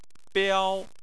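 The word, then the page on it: biao: tone 1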